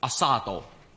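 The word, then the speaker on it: asado: 我上网 search 过嘅，正確嘅读音应该差唔多噶啦。阿沙多，阿沙多。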